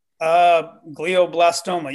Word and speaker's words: glioblastoma: For those of you that haven't heard me talk about this or that don't know what's going on here, Seth has glioblastoma. Is that, am I saying that right? Brain cancer. – Yeah. – Glioblastoma. Am I saying that right? – Glioblastoma. Yeah. – Blastoma. There's thank extra you. – L in there. Yeah.